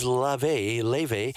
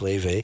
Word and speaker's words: Laufey: She's got together, this is Leve. I think that's how it's pronounced. It's spelled Laufey, but I think it's pronounced Leve.